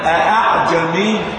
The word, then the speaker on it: أأعجمي: تنطق همزة ثم تسهل الثاني هكذا، أعجمي وعربي، أنا لم أقل أها،